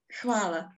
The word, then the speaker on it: hvala: Or hvala. – Hvala. – Hvala. It's with H. It starts with H.